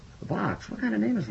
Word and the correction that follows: Vax: First of all, it's Vax.